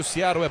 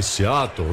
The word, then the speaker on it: Seattle: Aliás, você falou em Seattle, né? Você, claro, traz a pronúncia local, né? Você T com som de R.